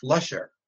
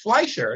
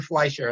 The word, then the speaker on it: Fleischer: And this guy comes in and says, well, you know, those guys, their last name is pronounced Flusher. I said, Flusher? I'm like, no, it's Fleischer. It's always been Fleischer.